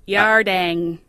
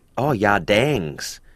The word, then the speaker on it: yardang: Yardings, which is... – Where is it? Yardang. – Yardangs.